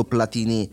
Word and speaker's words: Platini: Le sue entrature transalpine gli hanno segnalato da tempo questo Platini, capitano dei Bleu e stella del Saint-Étienne, campione di Francia in carica. L'accento sulla I finale è la francesizzazione di un cognome italianissimo.